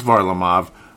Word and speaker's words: Varlamov: Varlamov, as I'm finally saying it correctly, even though I've obviously been watching the games, but I just the pronunciation, I've heard it back and forth. It's Varlamov, just a son of a gun.